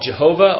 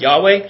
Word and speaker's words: Yahweh: And this word Yahweh, the Lord, sometimes called Jehovah, although it's not an accurate verbalization, but what's behind that is this word for Lord, the YHWH, which is most likely pronounced something like Yahweh.